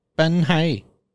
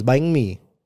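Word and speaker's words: bánh mì: But you're saying it. It's very nasal. It's bang mi.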